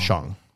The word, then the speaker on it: Shang: But anyways, so wherever region this character hails from, the proper pronunciation is Shang.